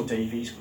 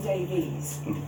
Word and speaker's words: Davies: The pronunciation in Wales would be exactly the same. There would be no one in Wales would call anyone Davies.